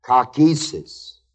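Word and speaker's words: kakesis (καύχησις): The word boasting is the Greek word K-A-U-C-H, long E-S-I-S, kakesis, which means the act.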